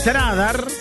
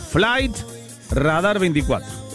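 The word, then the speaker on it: Flightradar24: Fly, en inglés, como se escribe en inglés, Fly. Tradar, Tradar, o en realidad sería Flight Radar. Flight Radar 24. Así sería, Flight Radar 24.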